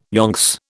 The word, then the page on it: yonks: British English